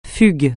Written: fugue

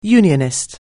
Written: Unionist

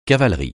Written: cavalerie

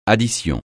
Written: addition

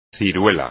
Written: ciruela

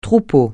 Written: troupeau

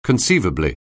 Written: conceivably

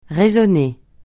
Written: résonner

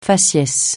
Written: faciès

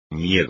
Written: miedo

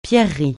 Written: pierreries

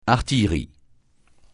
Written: artillerie

